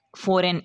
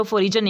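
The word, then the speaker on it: foreign: Silent letter जी है, इसके लिए कुछ एग्जांपल्स हैं, जैसा कि फॉरेन, इसको फॉरेन पढ़ना है, इसको फॉरिजन नहीं पढ़ना, जी जो है ये साइलेंट हो जाता है।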